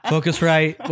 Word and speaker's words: Focusrite: Yeah, it's the phosphorite. Well, I know I'm probably saying that wrong. Focusrite, whatever.